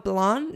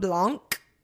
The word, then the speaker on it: Blanc: It is Kim Crawford Sauvignon Blanc and I just discovered it's Savion Blanc, not Blanc. I've been saying it with the C, but apparently the C is silent.